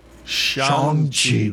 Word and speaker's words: Shang-Chi: Shang-Chi. That's how they're saying it in the ads. And I'm like, shit, I've been saying it wrong the whole time.